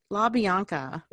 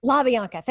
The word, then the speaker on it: LaBeouf: LaBeouf. – LaBeouf. Thank you.